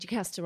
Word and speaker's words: Jacasta: You said Jacaster right, but Newnam is the...